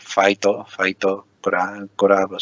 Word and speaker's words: Faito Kurabu: Fight Club, igualmente, pero sí que en la pronunciación así del inglés, bueno, el japonés tiende a terminar con vocales, entonces en muchas ocasiones, entonces sería así, el Faito Club, sí.